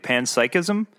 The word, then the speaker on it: panpsychism: panpsychism.